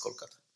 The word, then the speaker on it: Kolkata: Yeah, and it's recently has been changed from Calcutta to Kolkata officially in India. – Okay. – So that's why we like to prefer it as, like to pronounce it as Kolkata.